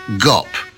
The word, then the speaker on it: GOP: pronouncing it GOP to rhyme with You're the Top.